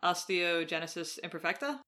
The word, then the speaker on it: osteogenesis imperfecta: So just because we haven't said it, I just want to make sure I'm pronouncing osteogenesis imperfecta. – Right. Yes. – That's the correct. – That sounds correct.